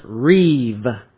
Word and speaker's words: rib: We've been working in chapters 4 through 10, which follow the Reeb format. It's spelled R-I-B, but the B sounds like a V in the Hebrew, so it's pronounced Reeb.